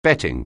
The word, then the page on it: bet: anglais